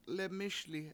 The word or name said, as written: lemISli